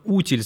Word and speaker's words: утиль: Причем посуду и фигурки, готовые под роспись, называют забавным словом «утиль». Именно «утиль» с ударением на «у».